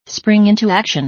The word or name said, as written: spring into action